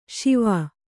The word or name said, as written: ಶೇವ